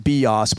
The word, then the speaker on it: bios: One of the words for life is what we would say, the word is bios. So they, I think they pronounced it bios, but you'll understand, B-I-O-S, bios, that's a word for life.